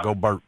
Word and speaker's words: Gobert: Big Perk, I like the way you say Gobert. Like, you don't even play with that French pronunciation. You just go straight in on Gobert.